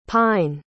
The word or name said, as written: pyne